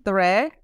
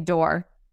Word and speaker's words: Doré: Did I say it right, Doré? – You did. Yes, you did. – So welcome to coffee number 5 first. And first of all, I have to ask you the accent, because that's something that we are not used to see in English. Tell me how people mispronounce it. – Oh my gosh. That's such a funny question. I think the funniest one is that people just say door.